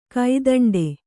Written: ಕೈದಂಡ

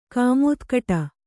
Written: ಕಮತಕಟ್ಟೆ

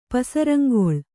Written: ಪಸರಂಗೊಳ್

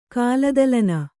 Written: ಕಾಲದಲನ

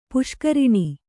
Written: ಪುಷ್ಕರಿಣಿ

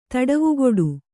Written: ತಡವುಗೊಡು

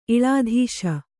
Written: ಇಳಾಧೀಶ

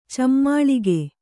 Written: ಚಮ್ಮಾಳಿಗೆ

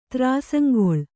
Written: ತ್ರಾಸಂಗೊಳ್

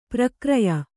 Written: ಪ್ರಕ್ರಯ

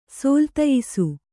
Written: ಸೋಲ್ತಯಿಸು

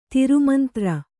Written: ದುರ್ಮಂತ್ರ

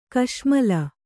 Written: ಕಶ್ಮಲ

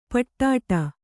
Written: ಪಿಡ್ಡ

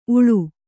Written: ಊಳ್

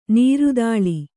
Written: ನೆರೆದಲೆ

